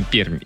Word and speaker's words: Пермь: Да, и даже Руслан пишет, Пермь, выделяя между R и M большой мягкий знак. Как бы в официальном названии не пишется, но произносится Пермь именно.